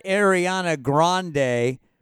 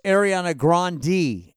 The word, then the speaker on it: Ariana Grande: By the way, it's not Ariana Grande. Her name is actually pronounced Ariana Grande. Ariana Grande.